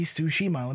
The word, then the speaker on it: Tsushima: Now the thing is, naming convention with with with uh, Japanese words generally follows the same convention. So it's not a two tsunami; it's a tsunami. So my guess would be go following that name that that pronunciation convention. It would be Tsushima, and that's the way I've pronounced it since I've seen it.